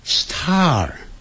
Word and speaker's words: star: 不要管它，这些字都不要管，我们只要学会 Star。Star 因为 STAR 有一个 R 的音结尾的时候是要卷舌的，而且 S 后头有那个 T 的时候可不要念成 star，那个 T 就变音了，变成 D 的音 star。